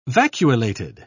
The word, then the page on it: vacuolated: British English